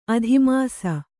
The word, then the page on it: ಅಧಿಮಾಸ: adhimāsa